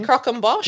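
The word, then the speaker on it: croquembouche: Now, how do you say that again? – Croc and Bosch. – Yes, that's it, style, as a savoury celebration cake.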